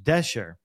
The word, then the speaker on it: Dashjr: Heel veel mensen zeggen het verkeerd, Bart, maar de achternaam van Luke spreek je uit als Dasher. Want ik zei natuurlijk vorige week Luke Dash Jr. Ja. – Omdat dat ook zo staat in principe, maar het is Luke Dasher.